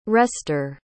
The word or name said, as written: rester